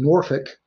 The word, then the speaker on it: Norfolk: Now, for those listening, Norfolk. – You're saying it right. – Norfolk, Virginia, is actually spelled Norfolk. – Right. – Right? – And very few people say it right if you don't live there.